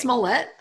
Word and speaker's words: Smollett: Oh, it's Smollett. Oh, I've been saying it wrong my whole life.